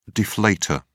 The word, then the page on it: deflator: British English